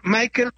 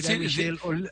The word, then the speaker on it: Michael: Él se dice Michael Olise, porque así lo pronuncia él por haber vivido en Inglaterra. – Michel Olise. En lugar de Michel, Michael. En lugar de Michel, que es el francés, Michael, por haber nacido en Inglaterra.